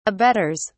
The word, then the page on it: abettors: British English